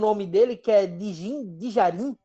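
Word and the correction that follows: Din Djarin: Din Dijarin.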